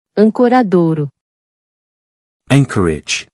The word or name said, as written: Ancoradouro